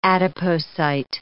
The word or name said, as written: adipocyte